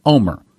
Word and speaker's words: Olmert: Let me spell it again, O-L-M-E-R-T. However, I was in Israel. I heard people pronouncing his name. And in Israel, you pronounce his name Omer, just like O-M-E-R.